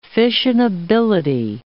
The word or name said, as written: fissionability